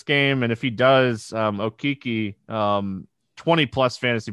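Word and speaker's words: Okeke: I'm gonna go Okoki. I keep saying his name wrong. And I was at the Magic game last night, and I'm still O-Kiki. Saying Okiki.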